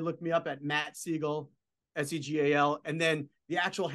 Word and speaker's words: Segal: So on social, you know, we have our at Segal Law Firm. It's S-E-G-A-L. Sounds like the bird, but it's spelled S-E-G-A-L Law Firm.